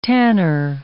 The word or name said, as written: tanner